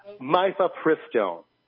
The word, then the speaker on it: Mifepristone: The name of the book is From Malthus to Mephipistone, which I now mispronounced, Mifepristone, which is an abortifacient pharmaceutical.